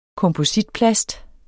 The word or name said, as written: kompositplast